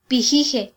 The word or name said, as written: pijije